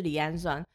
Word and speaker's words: 离氨酸: 他就说离令。然后我就想说，我不知道我现在发音对不对，我一自始至终我没办法学会这些东西。他就说这这就是氨基酸啊，我说是离氨酸吗？他说对对对，是离氨酸。